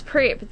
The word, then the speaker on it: PrEP: Is that how you pronounce it? – Oh, yes, PrEP. That's going to be really cool.